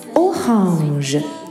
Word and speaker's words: orange: 同理，橙色在法语中是 orange，orange。跟英语的写法一样，但是发音不同，小朋友们要注意啦。 orange，橙色，而橙色在法语中同时可以作为橙子这种水果的名词形式。